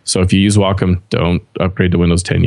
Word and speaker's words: Wacom: Is that how you pronounce it? Wacom? – Yeah.